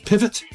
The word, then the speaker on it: PVT: It's a band called PVT, pronounced Pivot. They're from Australia. They had to change it to PVT because there's already an American band called Pivot, the full word. So it's still Pivot without being Pivot.